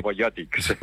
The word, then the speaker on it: Talarobionòtics: que integren l'equip Talarobionòtics, no sé si ho he pronunciat massa bé. – Talarobionòtics.